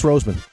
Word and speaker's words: Roseman: It's Roseman. – Oh, you got it. – Well, for some reason, I called him Raceman one time or twice.